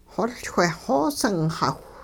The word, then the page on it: xoLchweh xoosingxawh: hupa